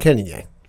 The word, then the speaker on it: Cannonier: Jared Cannonier.